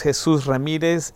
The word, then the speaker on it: Jesus Ramirez: My name is Jesus Ramirez, J-E-S-U-S-R-A-M-I-R-E-Z.